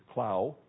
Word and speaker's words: Clough: C-L-O-U-G-H. I used to pronounce it Clough, but I'm told it's Clough, pronounced Clough.